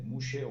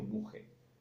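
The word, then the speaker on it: muxe: El tercer género que llamamos nosotros, en zapoteco se dice mushe o muje. – Se escribe con X.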